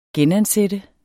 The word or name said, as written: GENANSATTE